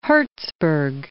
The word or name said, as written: Herzberg